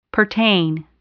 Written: pertain

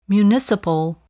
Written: municipal

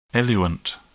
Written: eluent

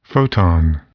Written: photon

